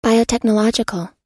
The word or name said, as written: biotechnological